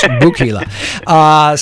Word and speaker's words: Wukila: Rhymes with tequila. It's Wukila.